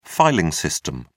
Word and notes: filing system: British English

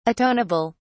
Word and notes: atoneable: British English

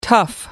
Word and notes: tough: American English

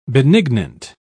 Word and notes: benignant: British English

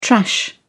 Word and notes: trash: British English

